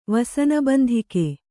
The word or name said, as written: ವಸನಬಂಧಿಕೆ